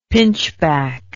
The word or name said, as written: pinchback